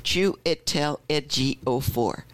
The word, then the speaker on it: Chiwetel Ejiofor: So I wrote Chiwetel Ejiofor, and the number 4. Chiwetel Ejiofor.